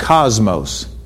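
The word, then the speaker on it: kosmos: The word world in this verse in the Greek is pronounced cosmos.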